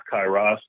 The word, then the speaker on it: Kairos: I believe in the Greek it's pronounced Kairos, but, you know, I pronounce whatever I want to pronounce.